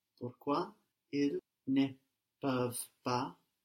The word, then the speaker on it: peuvent: This is spelt P-E-U-V-E-N-T. E-N-T on the end of verbs in French isn't pronounced, but it is making us hear that V before it, no? P-E-U-V-E-N-T, peuvent.